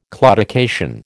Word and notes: claudication: American English

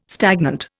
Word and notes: stagnant: Indian

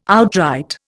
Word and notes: outright: Indian

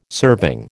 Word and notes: serving: American English

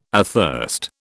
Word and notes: athirst: British English

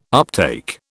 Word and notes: uptake: British English

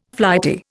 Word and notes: flighty: Indian

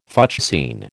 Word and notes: fuchsine: American English

